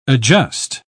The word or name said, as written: adjust